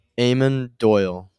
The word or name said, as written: Doyle